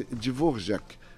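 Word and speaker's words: Dvořák: Então, ele nasceu onde hoje é a República Tcheca é um compositor chamado Antonin Dvorak. Escreve Dvorak, né, com AK, mas pronuncia-se Dvorak.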